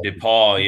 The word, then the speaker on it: DePauw: Am I saying that right? – Yep, DePauw, yeah.